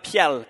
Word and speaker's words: piel: if you were to look into the Hebrew, Solomon uses something called the piel form in Hebrew. If you want to go ahead and say it at home, you can say it out loud. Piel, P-I-E-L, piel.